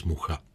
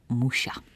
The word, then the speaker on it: Mucha: Francouzi mluví přímo o stylu Mucha a hezky to vyslovují jako Muša. – Slyšel jsem dokonce i variantu Míša, ale to bude zřejmě kraj od kraje.